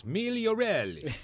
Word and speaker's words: Migarelli: You got to say it right, though. It's Migarelli. – Sorry, Migarelli. – Now you see, now you got it.